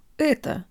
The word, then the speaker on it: это: When we first start studying Russian, our это sounds more like это. This is because native English speakers tend to retract their tongue from its position at rest when they speak, anchoring it to their upper molars in the back of their mouths and allowing the tip of the tongue to move freely.